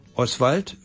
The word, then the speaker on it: Oswald: Oswald wird anders betont, Oswald, und Sie merken, Ost, das O wird schwach ausgesprochen und dann bleibt das Schwald übrig.